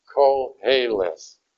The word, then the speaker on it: Koheleth: The book is called Koheleth, all right? Even though it is a Q and an O, in most English words, Q is next to U, Q-U.